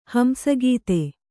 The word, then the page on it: ಹಂಸಗತಿ: hamsa gati